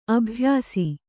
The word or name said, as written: ಅಭ್ಯಾಸ